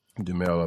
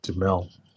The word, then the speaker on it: Duhamel: Duhamel. – Duhamel, I believe it is. – Duhamel? – Yeah. – Duhamel. Who the hell says it to Mel? There's two syllables there, people.